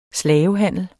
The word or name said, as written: slavehandler